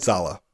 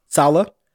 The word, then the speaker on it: Saleh: So they hired Robert Sella, I think that's how you say his name, and Saleh. Saleh.